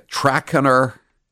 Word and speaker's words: Trakehner: Bellissima is a trackener. I hope I'm pronouncing that correctly. T-R-A-K-E-H-N-E-R.